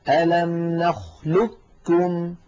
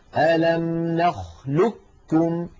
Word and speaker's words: نخلقكم: إذن أعود فأذكركم بنطق هذا الحرف هكذا ألم نخلقكم ألم نخلقكم كاف مشددة.